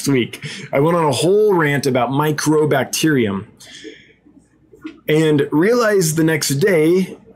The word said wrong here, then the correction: mycobacterium: when Michael Wentworth brought it to my attention that I've been saying it wrong, that it's mycobacterium, not microbacterium.